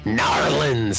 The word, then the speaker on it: New Orleans: On Sunday, sources say NBA going forward with a plan to take over the New Orleans Hornets. – Narlins. Say it right, man.